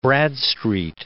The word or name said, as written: Bradstreet